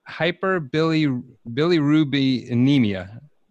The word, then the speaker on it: Hyperbilirubinemia: He had hyperbilirubinemia, I may have screwed that up.